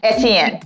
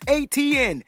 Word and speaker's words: Etienne: His last name is Etienne. – It's dope. How do you say it? – Etienne. – Yeah. No, that's not how they said it. That wasn't it.